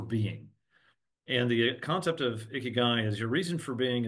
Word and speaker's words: Ikigai: And it's basically, I pronounce it Ikigai. And my publisher went down this whole path of how do you pronounce that. And they actually made me choose. They said, there are Japanese people on either side of how to pronounce this. And so I chose Ikigai, although it sounds dumb.